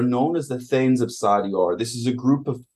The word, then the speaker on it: Sardior: Sardior, according to the internet, is pronounced Sardior, and was the dragon god of night, psionics, and secrets, and he was also the master of the gem dragons.